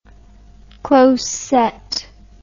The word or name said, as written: close-set